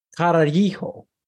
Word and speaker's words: Carajillo: So this is called I found it on a recipe website that I frequent called the Carajillo. Carajillo. Obviously, you pronounce the two L's in Spanish as E.